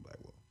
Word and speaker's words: Blackwell: Yahoo, YAHU_Rock, ROCK, and my last name, Blackwell.